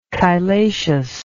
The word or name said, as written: chylaceous